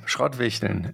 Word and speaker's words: Schrottwichteln: I probably will pronounce it wrong, but it's Schrottwichteln. – Yeah, Schrottwichteln, exactly.